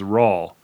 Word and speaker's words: Raul: So now Raul, they say very specifically, it's spelled Raul, but it says very specifically in the book that it's pronounced like Paul. So it's Raul, right?